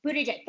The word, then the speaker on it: Buttigieg: And so, I love that Pete Buttigieg, how do you pronounce it? Is it Buttigieg? Buttigieg? – Buttigieg? – Buttigieg. Thank you.